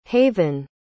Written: havyn

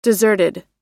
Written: deserted

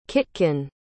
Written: Kitkun

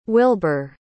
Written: wilbur

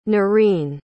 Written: nareen

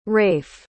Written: rafe

